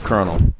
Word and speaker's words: Colonel: Colonel is pronounced Colonel. It is weird.